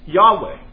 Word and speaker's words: Yahweh: And this means, tetragrammaton actually means four letters, and it's pronounced Yahweh.